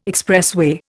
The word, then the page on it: expressway: Indian